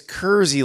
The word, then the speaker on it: Kersey: You're saying it's Kersey? Sort of like, it's Kersey, like Jersey.